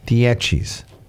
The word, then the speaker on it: Dieci: How do they pronounce that? – Dieci's, right? – Dieci, yeah, 10. – Yeah, 10. That means 10 in Italy.